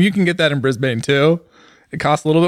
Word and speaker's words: Brisbane: You're supposed to say Brisbane, by the way.